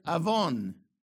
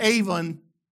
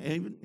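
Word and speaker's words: avon (עָוֹן): So the Hebrew for Psalm 51, okay, gets the word iniquity from the word avon. Not avon. Avon calling, avon, you know. The accent is on the O, avon, okay?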